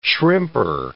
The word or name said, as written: shrimper